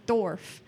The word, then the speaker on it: dwarf: I pronounce it like sword or like Steven Dwarf. Yeah. Yeah, like you really don't throw the W in there at all.